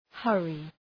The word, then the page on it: hurry: ‘hɜ:rı; ‘hʌrı